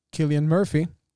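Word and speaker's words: Cillian Murphy: Oppenheimer själv ska spelas av Killian Murphy, om man, det är så man uttalar hans namn, som ju är en favorit hos Nolan.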